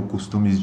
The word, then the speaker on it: costume: que costumes que nós costumamos fazer ou costumes de uma cultura. – Não, não é isso. Costume na verdade, essa é a pronúncia costume, apesar de se escrever o mesmo como costume em português.